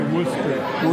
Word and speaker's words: Worcester: How do you say Worcester? – Worcester. Yeah, you got it. – Not Worcester like the stuff you put on your steak.